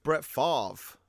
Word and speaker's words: Favre: Brett Favre.